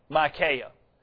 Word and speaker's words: Micaiah: Bible students, by the way, that is the way you pronounce his name, Micaiah.